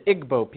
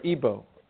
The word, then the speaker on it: Igbo: You have the Igbo people, or Igbo, pronounced either way.